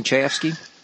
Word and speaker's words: Chayefsky: That's how you pronounce it, Chayefsky.